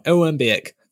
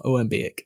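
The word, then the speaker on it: Omam-Biyik: And this is, I'm going to butcher this name. This is Oman Bayek. – Oman Bayek. – There we go. That can go in the cut now. Oman Bayek.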